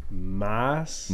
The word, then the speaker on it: Maas: Yeah, and definitely sounding out does if you were to say my name, which is spelled M-A-A-S, is Moss.